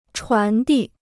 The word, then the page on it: 传递: chuán dì